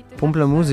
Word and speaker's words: Pomplamoose: Le nom du groupe vient du mot français pamplemousse, Pomplamoose est une approximation orthographique anglaise de la prononciation française de ce fruit.